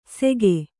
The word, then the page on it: ಸೆಕೆ: Kannada